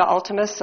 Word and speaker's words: Altemus: For the record, my name is Julia Altemus. The last name is spelled A-L-T-E-M-U-S.